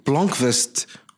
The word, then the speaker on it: Blomkvist: But Michael Blomkvist Yeah, I think I said it properly.